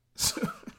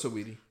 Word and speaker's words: Saweetie: Is it Saweetie or is it Sweetie? – Saweetie. just say the shit. That's it. Just say how you say it. – Saweetie. – But half the time, that ain't it. – Nah, that's it though. It's Saweetie.